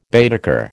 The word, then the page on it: Baedeker: American English